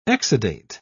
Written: exudate

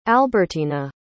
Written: albertyna

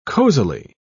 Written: cozily

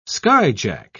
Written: skyjack

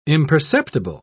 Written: imperceptible

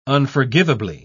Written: unforgivably

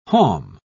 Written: halm

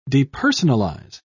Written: depersonalize

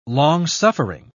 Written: long-suffering

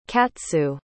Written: katsu

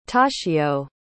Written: toshio

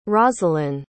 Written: rosalin

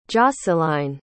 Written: joscelyne

